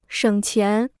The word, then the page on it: 省钱: shěng qián